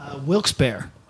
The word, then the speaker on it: Wilkes-Barre: How do you pronounce this town in Pennsylvania? – Wilkes-Barre. – Okay, good.